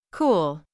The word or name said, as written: cool